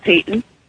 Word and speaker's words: Peyton: My name is Peyton. – Peyton? – Yes. – Peyton. Like Peyton Manning? – Yes.